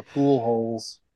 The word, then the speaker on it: Pujols: Pool holes. – Oh man. – But yeah, that guy's name is spelled Pujoles or something, right? – Yeah. – But it is pronounced pool holes.